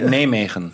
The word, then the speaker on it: Nijmegen: Wie, kannst du nochmal sagen, wie nee, das richtig? – Nijmegen. – Nijmegen. Holländisch ausgesprochen.